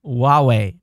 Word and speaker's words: Huawei: Huawei. Huawei. Oh, man. I would have continued. You know, it's sometimes. It's, I guess that H is silent in the oo as wah.